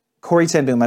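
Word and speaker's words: Corrie ten Boom: I am reminded of the story of Corrie Den- ten Boom. Corey Tenboom, I mispronounced her name.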